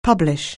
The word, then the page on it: publish: anglais